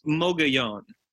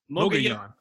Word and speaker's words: Mogollon: So, is it a Spanish word? So if it is, then it's Mogollon. – Oh, that's a good point. Yeah, yeah. – There's a pronunciation guide here. I think it looks like it is Mogollon.